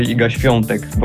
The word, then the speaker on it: Iga Świątek: Iga Swistik. Is that how you say it? – No, in Polish you say Iga Świątek, but that's.